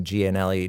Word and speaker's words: Gianelli: So yeah, it's Christian, C-H-R-I-S-T-I-A-N, and Gianelli, G-I-A.